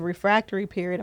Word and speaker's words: refractory period: And the refractory period, am I saying that right? – Oh, you got that.